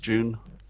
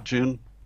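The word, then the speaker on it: Joon: Millennials have a new way to travel in style when Air France's new airline June. Do we decide, is it pronounced June? – Mm-hmm. – Are June. We just going to call it June? – Yeah, why not? – Yeah.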